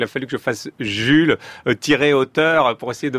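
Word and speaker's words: Jul: j'avais quelqu'un dans la compétition qui était le rappeur Jules, qui ça s'écrit pareil, ça se dit Jules comme ça.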